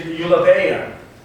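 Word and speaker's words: eulabeia (εὐλάβεια): The Greek word is eulabeia.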